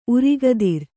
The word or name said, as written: ಉರಗಧರ